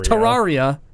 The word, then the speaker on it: Terraria: Um, next up, Terraria. Is that it? – Is yeah. That how you say it? I've always pronounced it Terraria. – Terraria. Terraria. Minecraft 2D, whatever you want to call it.